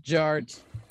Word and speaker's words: Jart: DJ Art with two T's for a double dose of that tink tink. The D is silent, so it's just Jart.